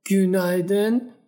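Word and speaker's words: günaydın: خب میشه اینو برامون بازش کنی این گون با یو هستش درسته با یو که دو نقطه بالاش داره پس اینو باید ما او تلفظ کنیم هم ای گونلر و هم گونایدن با او تلفظ میشه درسته درسته تلفظت خیلی درسته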